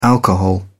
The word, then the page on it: alcohol: British English